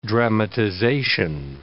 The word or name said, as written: dramatization